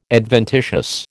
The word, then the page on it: adventitious: American English